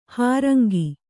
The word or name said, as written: ಹಾರಂಗಿ